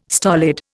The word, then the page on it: stolid: Indian